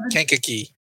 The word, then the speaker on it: Kankakee: How do you pronounce it? – Kankakee.